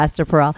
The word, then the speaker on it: Esther Perel: I remember hearing Esther Perel. Is that how you say her name? I love her. Do you like her? Esther Perel. – Uh-huh. Yeah, Esther Perel.